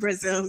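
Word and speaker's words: Brazile: Brazil, I think. – Brazil, yeah.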